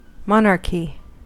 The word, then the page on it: monarchy: American English